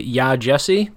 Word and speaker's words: Yaa Gyasi: It is by Yaa Gyasi. Did you confirm that pronunciation, by the way? I feel like you did. – I did. Okay. I watched a video where she said her own name.